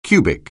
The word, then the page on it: cubic: anglais